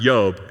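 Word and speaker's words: Yob (איוב): And so anyway, Job in Hebrew is pronounced Yob, and the first letter is an Aleph, which is the first letter of the Hebrew alphabet, all right?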